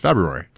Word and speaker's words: February: Well, I know that there's an R in there, but I think it's not pronounced, right? – Well, it's not pronounced if you're mispronouncing the word, but it's an R. It's February.